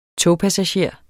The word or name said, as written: togpassager